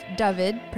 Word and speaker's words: Dyfed: So, originally from the Welsh capital of Cardiff, Dyfed, pronounced Dyfed, is an award-winning actor, voice artist, and radio journalist with a passion for storytelling, movies, and sports.